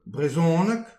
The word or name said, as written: brezhoneg